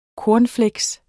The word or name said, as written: CORNFLAKES